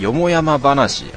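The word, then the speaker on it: 四方山話: はい、あとは巷の噂とか、てかこれ何、都市伝説みたいなやつとか噂、そういう系でいいのかな、なんかまあそういう話みたいなんですけども、はい、よもやま話、あそうか、よもやま話っつうんだこれ漢字で、あ知らなかった俺、地方三話と書いてよもやま話と読むらしいですね、自分知りませんでした、漢字で書く場合はどういう風に書くのか。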